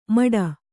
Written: ಮಡ

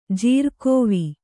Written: ಜೀರ್ಕೋವಿ

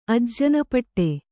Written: ಅಜ್ಜನಪಟ್ಟೆ

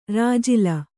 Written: ರಾಜಿಲ

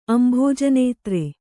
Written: ಅಂಭೋಜನೇತ್ರೆ

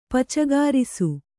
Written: ಪಚಗಾರಿಸು

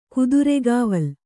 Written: ಕುದುರೆಗಾವಲು

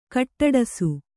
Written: ಖಡ್ಡಿಸು

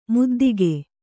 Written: ಮುದ್ದಿಗೆ

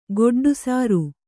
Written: ಗೊಡ್ಡುಸಾರು

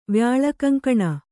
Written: ವ್ಯಾಳಕಂಕಣ

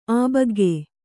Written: ಆಬಗ್ಗೆ

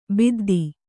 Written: ಬುದ್ಧ

